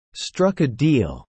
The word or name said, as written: struck a deal